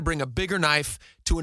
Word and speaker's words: Naif: His name his name is pronounced Knife.